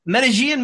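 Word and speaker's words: Medellín: Medellin Mets is Medellin. No, Medellin. – Medellin Mets fan. Yeah. Got to say it right. Medellin. Don't say it like they said in Entourage, right? It's Medellin.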